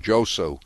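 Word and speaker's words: Djossou: This is a woman named Charlotte Josu, D-J-O-S-S-O-U. I think the D is silent. Josu.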